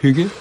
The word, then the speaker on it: hygge: Hygge, jak to będzie po duńsku? – Also correctly pronounced. Hygge, yes.